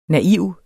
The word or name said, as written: naiv